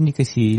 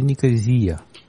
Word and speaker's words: Никосия: Она так и читается, Никосия или Никозия? – А, вообще, на самом деле, Никосия это придумывали колонизировавшие в свое время англичане. Вообще город называется Левкосия.